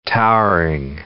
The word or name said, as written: towering